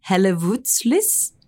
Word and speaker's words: Hellevoetsluis: Now berthed in Hellevoetsluis. Do you reckon that's right? – Yeah, sounds good to me.